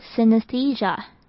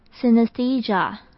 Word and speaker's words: synesthesia: 第二个 e 这边我们会发成长母音 e 的声音，整个字念起来就是 synesthesia, synesthesia.